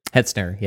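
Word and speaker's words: Hetzner: And you know, you guys know this you know, firsthand with was it Hertzner? How do you pronounce it? – Hertzner, yeah.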